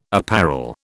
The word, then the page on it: apparel: British English